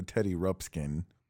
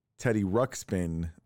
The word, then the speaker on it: Teddy Ruxpin: because we actually discussed later that I was pronouncing Teddy Ruxpin the wrong way. So I'm not an idiot. I know it's not called a Teddy Rupskin.